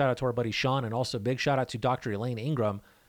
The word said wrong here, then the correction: Ingham: Ingham, there's no R in there. I've been saying it wrong the whole time. I just read it. Elaine Ingham.